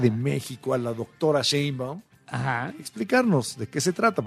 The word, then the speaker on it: Sheinbaum: Pero ya habló de ella la presidenta Sheinbaum, como le dicen algunos, porque no saben pronunciar su apellido. Sheinbaum.